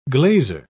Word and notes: glazer: British English

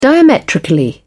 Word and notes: diametrically: British English

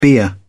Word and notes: bier: British English

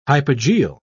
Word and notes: hypogeal: British English

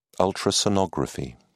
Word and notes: ultrasonography: British English

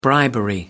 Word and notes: bribery: British English

